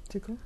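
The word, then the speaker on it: TIKL: It's T-I-K-L. How would you say it? Tickle? – Tickle.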